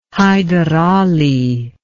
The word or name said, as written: Haidarali